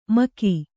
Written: ಮೆಕ್ಕೆ

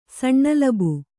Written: ಸಣ್ಣಲಬು

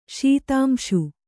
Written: ಶೀತಾಂಶು

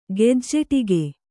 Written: ಗೆಜ್ಜೆಟೀಕೆ

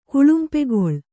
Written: ಕುಳುಂಪೆಗೊಳ್

